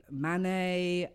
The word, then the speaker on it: Manet: Monet, Manet, Van Gogh.